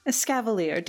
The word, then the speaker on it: Escavalier: Escavalier, dear.